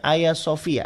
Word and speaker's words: Hagia Sophia: Kalau ditulisannya Hagia Sophia, tapi bacanya Hagia Sophia, adik-adik ya.